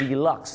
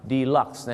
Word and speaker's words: deluxe: เสียงว่า deluxe นั่นเองครับ deluxe นะฮะไม่ใช่เดอร์ลุคนะครับ